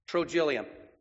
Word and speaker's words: Trogyllium: That's how you say that. Phonetically, I had to look it up. Trogyllium.